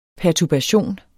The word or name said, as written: perturbation